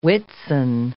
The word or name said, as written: Whitsun